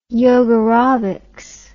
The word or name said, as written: yogarobics